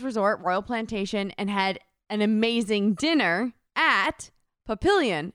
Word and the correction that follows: Papillon: Pap- it's French, so it's probably like papi- papillon.